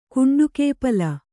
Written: ಕುಂಡುಕೇಪಲ

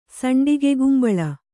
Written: ಸಂಡಿಗೆಗುಂಬಳ

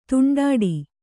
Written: ತುಂಡಾಡಿ

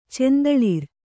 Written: ಚಂದಳಿರ್

